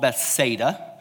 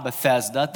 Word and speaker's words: Bethsaida: I found out it's called Bethsaida not Bethesda. That's a different place. Uh, my girlfriend was in Israel recently. She told me I was saying it wrong, so I'm trying to say it right. It's Bethsaida. Everyone say Bethsaida. Bethsaida, Bethsaida okay? It's different than Bethesda.